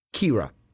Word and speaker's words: Kira: Yeah, so it's Kira. I was right. Because okay. It says here it's pronounced K-I-R-A.